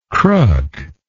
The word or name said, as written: crook